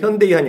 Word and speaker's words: 현대: 그러니까 걔네들이 발음할 때 일부러 처음에 현대에서 나갈 때, 썬데이 먼데이 현대이라고 했거든요. 아, 그렇게 광고를 했어요, 진짜로. 썬데이 먼데이 현대이 하니까 영어인지 아닌지 몰라요.